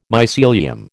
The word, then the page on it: mycelium: American English